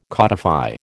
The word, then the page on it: codify: American English